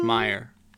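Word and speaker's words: Meijer: And I start heading down to Myers. – It's pronounced Meyer. – Huh? What did I say? – You said Myers.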